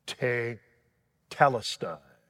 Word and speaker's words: tetelestai: It's pronounced te-talista. And it's pronounced in English, finished, finished, to telestai. I have gone the distance. I've gone the distance. Matter of fact, that tetelestai means that it's something that is perfected.